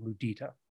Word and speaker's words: mudita: I don't know if my pronunciation is great because I've never heard it pronounced, but mudita. Mudita. – Okay. – Mudita. M-U-D-I-T-A.